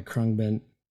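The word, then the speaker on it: Khruangbin: The song is Texas Sun by Krungbin.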